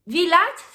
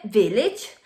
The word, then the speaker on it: village: No se dice village, se dice village. No se dice message, se dice message.